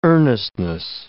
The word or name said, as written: earnestness